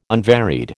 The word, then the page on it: unvaried: American English